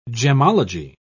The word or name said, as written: gemology